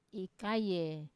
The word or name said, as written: kaye